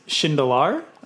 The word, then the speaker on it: Shindelar: So pronunciation, it's my last name is Shindelar. So close, but not quite. And then Osio, we say Osio Labs, O-S-I-O.